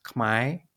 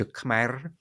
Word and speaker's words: Khmer: It's as pronounced in Cambodia in most dialects. It's pronounced Khmai, and that pronunciation is often favored in Cambodian diaspora communities. In other dialects, particularly dialects spoken in of Cambodian spoken in Northeast Thailand, the pronunciation may be closer to Khmer.